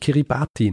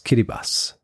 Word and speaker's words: Kiribati: Auch ein wichtiger Hinweis, ich habe ja über unterschiedliche Inselstaaten gesprochen im Pazifik und unter anderem einen kleinen Inselstaat, den ich Kiribati nenne. Ja? Tatsächlich spricht man das TI in dieser Sprache wie ein Doppel-S aus. Also eigentlich heißt es Kiribati.